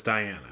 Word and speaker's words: Diana: It's like Princess Diana.